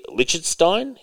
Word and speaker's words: Lichtenstein: The USPS recently released a commemorative series of stamps to honor infamous plagiarist Roy Lichtenstein.